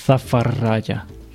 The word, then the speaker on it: Zafaradia: Z, miejscowość się nazywa Zafaradia. Jakby ktoś pytał, to się tak dziwnie wymawia, Zafaradia.